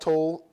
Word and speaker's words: Tolle: I think his last name is pronounced Tolle. German guy.